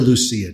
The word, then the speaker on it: Lucia: And one of the things we celebrated every year, we called it Santa Lucia Day. Some people call her Lucia, which is probably the right Italian pronunciation. Others just anglicize it to Lucy.